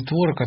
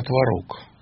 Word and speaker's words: творог: Мы с Авербахом так долго прожили, потому что оба говорили не творог, а творог.